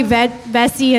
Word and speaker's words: Vesce: V as in Victoria, E-S-C-E. It's probably Vesce in Italian, but I'm from the South where no one can pronounce a freaking thing.